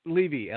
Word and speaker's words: Levy: You got it right. Mitch, yeah, it's Levy, L-E-V-Y.